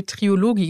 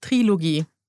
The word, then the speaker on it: Trilogie: Mich hat nämlich eine liebe Zuschauerin korrigiert, weil ich habe in der Story Trilogie gesagt, weil ich dachte, das heißt Trilogie. Nein, das heißt Trilogie.